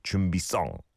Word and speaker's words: 준비성: And our producer Minnie says, you usually pronounce it with a 상시오, like 준비성, 성.